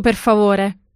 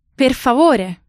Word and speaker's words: per favore: Un espresso, per favore. – An espresso, please. Okay, I think I got the first part. But what was the pronunciation for please? – Per favore.